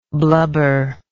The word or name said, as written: blubberingly